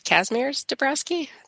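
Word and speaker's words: Kazimierz Dąbrowski: Kazimierz Dabrowski.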